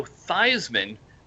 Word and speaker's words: Theismann: And you need to change your name to Joe Theismann because it rhymes with Heisman.